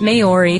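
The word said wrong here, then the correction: Māori: throughout the podcast, so we have to apologize for that and correct it. It is pronounced Maori, or at least that's an acceptable pronunciation, I think, for Yanks like us.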